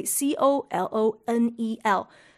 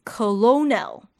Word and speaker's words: colonel: 如果是按照我们所熟悉的基本的英文发音的规则，我们可能会以为 C O L O N E L 是发成 colonel，大概是这样吧。但万万没有想到，英文实在是机智到一个不行，因为 C O L O N E L 正确的发音应该是 colonel，colonel，请问哪里有 R？